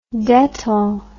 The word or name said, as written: dettol